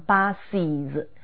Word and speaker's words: buses: 第三个，再以 s x s sh ch 等结尾的词呢，后面往往要读 is 的音，比如说 bus buses，注意这时候 s 和后头的 is 相拼就念成 buses。